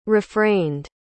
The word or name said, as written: refrained